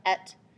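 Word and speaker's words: et (את): Um, the one word that they don't know how to translate from Hebrew is spelled aleph tav. The word is pronounced it's pronounced et.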